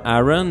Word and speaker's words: AaRON: Nous entendions la formation française Aaron, qui s'écrit A majuscule A minuscule R-O-N, les trois dernières lettres en majuscule. Donc Aaron, formé des musiciens Olivier Coursier et Simon Buret.